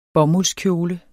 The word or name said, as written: bomuldskjole